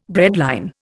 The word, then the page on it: breadline: Indian